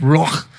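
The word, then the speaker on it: ruach (רוח): The word breath is the Hebrew word ruach. I can't even say it right, but it's ruach.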